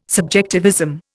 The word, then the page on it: subjectivism: Indian